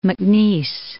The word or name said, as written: MacNeice